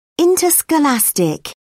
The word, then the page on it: interscholastic: British English